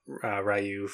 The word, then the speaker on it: Ryu: I almost said Ryu, and I was like, well, I'll use the American Ryu. I'll say it the correct way. – You'll say it the Japanese way.